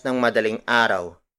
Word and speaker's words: ng madaling araw: When we refer to time during the early morning, which is from 12:01 AM to 5:59 AM, we add the phrase ng madaling araw.